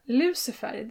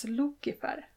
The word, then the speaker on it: Lucifer: Lucifer, det uttalet är ju medeltidslatin. På romersk klassisk latin så uttalas U alltid som O och C alltid som K. Mm. Så Lucifer skulle då ha uttalats Lucifer.